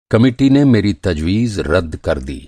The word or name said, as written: तजवीज़